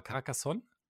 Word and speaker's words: Carcassonne: Carcassonne? – Kenne ich. – Habe ich es richtig ausgesprochen? – Hätte ich jetzt gesagt, ja. – Okay, sehr gut.